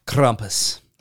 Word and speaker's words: Krampus: How much better of a Christmas you would have if he would pronounce Krampus the way he said he was gonna. – Krampus. – There we go.